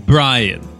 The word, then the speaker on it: Brian: Brian. Brian Sparks. – Oh, Brian with a B.